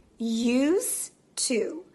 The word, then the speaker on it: used to: Used to. We don't really pronounce that final D sound because we connect it with the to. So used to, used to. We don't really say used to.